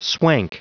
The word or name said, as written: swank